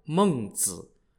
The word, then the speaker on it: Mengzi: Mencius's birth name was Mengke, M-E-N-G-K-E, and Mencius is just an English way of pronouncing his honorific title, Mengzi, spelt M-E-N-G-Z-I, which literally means Master Meng.